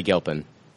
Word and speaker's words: Gilpin: Gilpin? – Betty Gilpin.